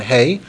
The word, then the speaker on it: Hey: Note the way we pronounce that letter, hey. Hey, it's as if there's a yud at the end.